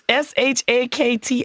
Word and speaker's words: Shakti: It's been adapted from the Hindu culture and is the symbol of the goddess Shakti. – You ain't even saying her name right. I hope she come strike you down. – It's Shakti. I do my Shakti.